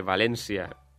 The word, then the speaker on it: València: Se podia escriure València tal i com pronunciem tots els valencians, que és en accent tancat.